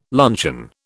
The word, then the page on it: luncheon: British English